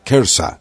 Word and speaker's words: Kersa: La forma inglesa del nombre del pueblo comúnmente se pronuncia Kersa, pero como palabra hebrea traducida o transliterada al griego, podía haber dado por resultado un sonido muy parecido a Gerasa.